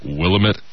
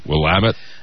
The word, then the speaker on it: Willamette: I apparently said Willamette instead of Willamette before. – Yes. And I'd like to apologize to the citizens of Portland. And the surrounding areas. – And the Willamette Valley.